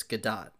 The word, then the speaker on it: Gadot: She says, doesn't rhyme with dough. The T isn't silent. It's Gadot.